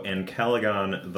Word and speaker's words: Ancalagon: So Ancalagon The Black. And I checked pronunciation of it for American English and for UK English. Ancalagon in UK English is basically Ancalagon, uh-huh. but with an accent.